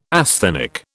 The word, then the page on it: asthenic: British English